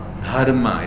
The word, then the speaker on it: Dharma: Dharma. It is aspirated.